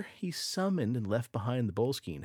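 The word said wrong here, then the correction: Boleskine: I think Lazarus has put the pronunciation down in the chat. Boleskin. – Boleskin House. – Yeah, there you go.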